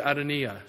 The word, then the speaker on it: Adonijah: I know it says Adonijah, but I went online and I was able to click on some little thing where this Hebrew guy said Adonijah. So that's how they think it's pronounced. So that's what we'll call him.